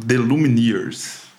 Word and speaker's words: The Lumineers: Lumin The Lumineers.